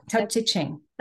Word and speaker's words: Tao Te Ching: It really combines many ways, many philosophies, like the I'm probably going to butcher this, but I think it's the Tao Tao Te Ching, Tao Te Ching, Tao Te Ching. Yeah, Tao Te Ching. That's how I would and Zen.